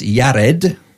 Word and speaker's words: Jared: Jared is pronounced Yared, and that's the servant or he shall humble himself.